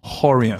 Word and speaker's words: Rorion: Carlos Elio Rorion. Rorion was one of the first ones. Rorion was in Brazil, we pronounce it Horion.